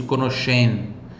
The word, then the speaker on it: conoscenza: SCE fica som de X, por isso conoscenza. Esse Z tem som de TS, tá? Igual pizza. Então, conoscenza, repita. Conoscenza. Conoscenza.